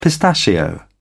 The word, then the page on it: pistachio: British English